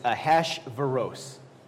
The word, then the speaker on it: Ahasuerus: And I wrote down how to pronounce this king's name, so I'm going to pronounce it once, and just refer to him as King Ahash. But it's Ahasuerus.